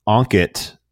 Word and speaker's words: Ankit: Did I say that right? You got it. Of AllyO. So before I before you intro, I have to know with a name like Ankit, spelled A N K I T.